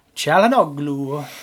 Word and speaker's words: Çalhanoğlu: Çalhanoğlu. That's the Italian accent pronouncing it. I won't do the Turkish one, I'll save that one.